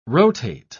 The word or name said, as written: rotate